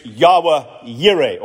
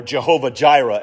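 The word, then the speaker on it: Yahweh Yireh (יהוה יראה): The Hebrew word Ebenezer and the Hebrew phrase Yahweh Yireh, or Jehovah Jireh, as it's mispronounced.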